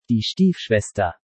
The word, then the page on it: Die Stiefschwester: German